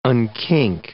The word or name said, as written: unkink